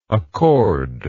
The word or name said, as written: accorder